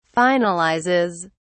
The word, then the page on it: finalizes: British English